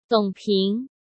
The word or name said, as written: Dong Ping